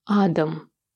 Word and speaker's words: Адам: С каким еще мужчиной?» «Да не бойся, Варенька, он замечательный, его зовут Адам». С ударением на первом слоге.